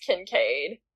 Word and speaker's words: Kincaid: It's not. Ryle. I went to high school with a girl whose last name is spelled that way, and it's Kincaid.